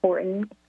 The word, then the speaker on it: Horton: And my name is spelled with two L's, Pamela Horton, and that's Horton as in Horton, here's a who.